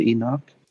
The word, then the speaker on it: Enoch: They said Enoch.